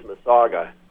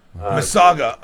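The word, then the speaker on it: massasauga: Well, the pronunciation of your snake is Masaga. – Masaga.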